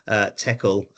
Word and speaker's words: Teckal: TECL.